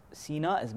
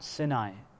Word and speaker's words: Sinai: Which is interesting because in one place Sinin is mentioned, in another place Sina is mentioned, which is referring to Mount Sinai or Sinai, depending on how you pronounce it.